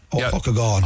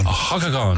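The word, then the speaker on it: Ó hEochagáin: Is it a Hockagon or O'Higgagon? – No, you go back to the first one. You got it perfect there on that one, Mick. Hockagon. – Hockagon.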